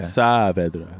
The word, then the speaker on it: Saavedra: Is your pronounce your last name Saavedra or? – Yeah, well, if you pronounce it in Spanish, it's Saavedra. – Saavedra.